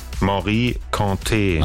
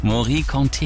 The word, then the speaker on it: Morikante: Wie wird's ausgesprochen? – Morikante. – Achso, guck mal, es war also falsch. Morikante, Yeke, alles klar.